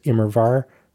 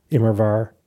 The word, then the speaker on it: Immerwahr: It's the W is pronounced as a V, so it is Immerwahr, Immerwahr, Immerwahr.